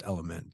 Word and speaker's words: LMNT: It's spelled L-M-N-T, pronounced Element, but it is an incredible recovery drink.